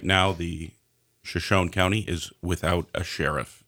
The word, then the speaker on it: Shoshone: Shoshone, I should have gotten. Okay, fine. Shoshone County. You're right. That is just as it's spelled.